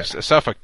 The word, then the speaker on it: Suffolk: Oh, drop the L is silent in Suffolk Downs. – What was that? – The L in Suffolk Downs. – Oh, it's silent. It's silent. – It's a silent.